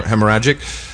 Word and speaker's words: hemorrhagic: Yeah, hemorrhagic.